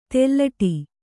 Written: ತೆಲ್ಲಟಿ

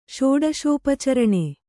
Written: ಷೋಡಶೋಪಚರಣೆ

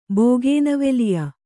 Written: ಬೋಗೇನವೆಲಿಯ

